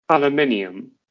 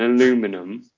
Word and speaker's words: aluminium: Or like aluminium. An aluminum. – Oh. – Well, there's an eye. You're missing an eye there.